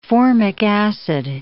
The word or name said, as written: formic acid